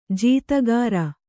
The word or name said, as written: ಜೀತಕಾರ